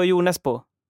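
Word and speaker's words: Jo Nesbø: jag tror på Jonesbo om man uttalar det så.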